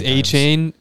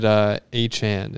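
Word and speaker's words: Achane: It's A-Chain. That's how everyone's been saying it for years, but it came up. – Years? How old is he? – He's a rookie. – Oh. Where do you go to college? – Mississippi State. – Okay. – But he came out this week and said A-Chan is actually how it's pronounced. – A-Chan, like 4chan.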